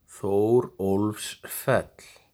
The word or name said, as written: Þórólfsfell